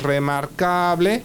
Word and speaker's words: remarkable: Recordamos que todo lo que termina en able o ible en castellano se escribe igual, pero el acento pasa a la sílaba anterior. O sea, si tenemos remarkable, remarkable.